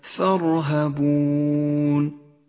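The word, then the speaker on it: ترهبون: اقرأ كلمة ثرهبون ولاحظ الألف الرمادية لا تلفظ، والراء التي لونت بالأزرق الغامق راء مفخمة لأنها سبقت بمفتوح، والواو البرتقالية التي ترمز هنا إلى المد العارض للسكون.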